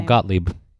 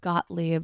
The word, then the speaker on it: Gottlieb: How do you pronounce her last name? – Oh, Gottlieb. – Gottlieb.